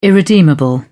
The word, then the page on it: irredeemable: British English